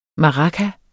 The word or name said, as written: maraca